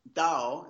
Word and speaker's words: Tao: Actually, the word Tao, it's pronounced with a D actually, is uh, it means in Chinese it means way or path.